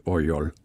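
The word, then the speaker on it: Oryol (Орёл): Nord for bulen, der har vi byen, som staves O-R-E-L, men det udtales Oryol.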